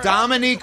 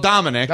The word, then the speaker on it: Dominique: He got both names wrong. Dominique Foxworth, he called Dominic.